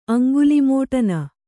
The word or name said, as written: ಅಂಗುಲಿಮೋಟನ